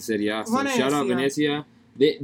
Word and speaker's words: Venezia: Venezia? – Venezia.